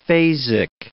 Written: phasic

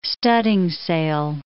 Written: studding sail

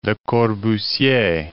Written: Corbusier, Le

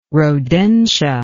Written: rodentia

